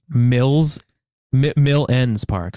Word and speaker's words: Mill Ends Park: Mill Ends Park. Sorry. Okay. Did I say it wrong? – No, you said it correctly. – Mill Ends Park.